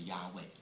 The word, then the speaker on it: Yahweh: 那么 wa 后面呢加上一个母音呢，很可能呢就是 e 的音，所以读成应该是 yahweh 哈。所以所有你按照希伯来文的读音的这个规则，应该读成 yahweh，但是犹太人呢从来不这样子的读，好，那么他们只敢读成 adonai。